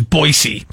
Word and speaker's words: Boise: Or actually, how they say it in Boise is Boise.